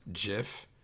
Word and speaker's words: GIF: the correct pronunciation by some stupid loser who invented it is GIF, but you don't put a G there if you want it to be called anything but a GIF.